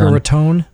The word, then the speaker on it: Boca Raton: Boca Raton? Did I say that right? Yeah, that's down there. – Boca Raton. – You know, it depends on where you're from, you know.